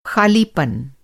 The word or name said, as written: खालीपन